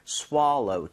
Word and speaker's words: swallow: swallow보다는 swallow 조금 입모양을 벌려서 좀 발음해 주시면 좋겠습니다.